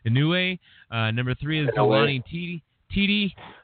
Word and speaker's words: Tete: Tete is how I always say it.